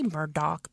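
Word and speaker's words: Murdaugh: They say Murdoch, but it's spelled Murdaw.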